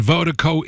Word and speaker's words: Virdiko: And Vertigo is spelled V-I-R-D-I-K-O. Pronounced Vertigo. That's V-I-R-D-I-K-O.